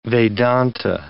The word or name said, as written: Vedanta